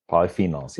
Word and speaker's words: polyphenols: And the Mediterranean diet, full of antioxidants and polyphenols. I don't pronounce that word often. Polyphenols or polyphenols? – You nailed it. Polyphenols, yeah.